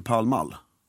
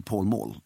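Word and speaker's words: Pall Mall: P-A-L-L. – P-A-L-L. – M-A-L-L. – Ja. – Och engelsmännen säger, we are going to Palmall. – Okej. – Okay. De säger inte, we are going to Paul Mall. Utan, bara så man vet det med uttalet.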